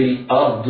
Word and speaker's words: الأرض: ولكم في الأرض مستقر، راء الأرض مفخمة والضاد رغم أنها مكسورة فهي مفخمة. راء الأرض مفخمة لماذا؟ لأنها ساكنة بعدها، وعلى القارئ أن يحترز من تفخيم همزة الأرض، ينطقها مرققة هكذا.